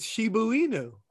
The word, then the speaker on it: Shiba Inu: I thought it was Shibana Inu. – It's Shiba Inu.